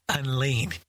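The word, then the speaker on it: En Lin: But the name that they gave me was Unlean. Which in English is spelled E-N and then L-I-N, but it means basically grace like streams in the desert.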